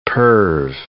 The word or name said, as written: perv